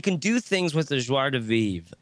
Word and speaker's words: joie de vivre: Joie de vivre.